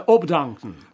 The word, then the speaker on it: abdanken: Rakouská lidová výslovnost spisovného německého A na začátku slova je totiž často O. Tak třeba německé slovo Arbeit. Takže česky práce. Ve Vídni málo kdy zaslechnete vyslovené jinak než Arbeit. A zrovna tak se v rakouštině, pokud takové označení mohu použít, neřekne abdanken, ale obdanken.